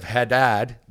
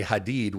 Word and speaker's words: Hadad: If people pronounce it instead of Hadad, they say Hadid, which in Arabic means steel.